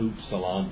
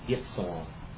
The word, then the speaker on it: upsilon: Not upsilon, but ipsilon. Okay? It's ipsilon.